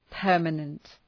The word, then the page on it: permanent: ‘pɜ:rmənənt